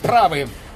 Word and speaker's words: правы: Правы, молодой человек, правы! Правы! Правы! В словаре ударений для работников радио и телевидения написано ударение правы!